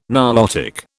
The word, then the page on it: Nilotic: British English